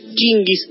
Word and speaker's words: Chinggis: Khan pronunciado, en realidad debería pronunciarse Chinggis Khan Chinggis cosa que si digo quién no tenemos ni la menor idea.